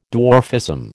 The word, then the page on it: dwarfism: American English